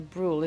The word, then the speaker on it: Brühl: She plays Sarah, and she's with Laszlo Kreisler, who's played by Daniel Brühl. Is that the way I pronounce his surname? – I think that's right.